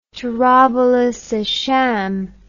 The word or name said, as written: Tarabulus esh Sham